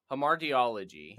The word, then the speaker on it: hamartiology: So we're going to talk, and I always say it wrong. So, hamartiology. – There you go.